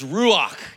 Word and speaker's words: ruach: And in the Old Testament, the word for spirit in Hebrew is ruach. You gotta kind of say it like got something stuck in the back of your throat.